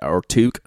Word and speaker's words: toque: Yeah, I got yelled at for not using the toque, which toque, I guess is the proper pronunciation, which I love that word, I just have a hard time saying it.